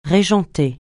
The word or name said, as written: régenter